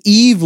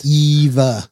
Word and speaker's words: EVE: What is Eve looking for on Earth in the film WALL-E? – That's not how it's pronounced. – Eva.